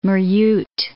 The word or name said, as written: Maryat